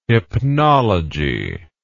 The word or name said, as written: hypnology